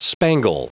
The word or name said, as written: spangle